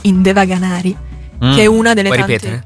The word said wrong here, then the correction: Devanagari: Anzi, Devanagari, scusatemi, faccio sbagliare. – Devanagari.